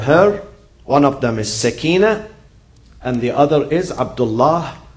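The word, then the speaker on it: سكينة: In Arabic, the right pronunciation is sakina, from tranquility, not sukaina. Many people say sukaina, it's not right.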